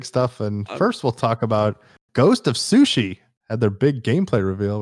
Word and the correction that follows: Tsushima: I'll actually say it right. It sounds weird. – Ghost of Tsushima.